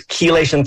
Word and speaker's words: chelation: You need, and what I'm talking about is chelation therapy. That's C-H-E-L-A-T-I-O-N, chelation therapy.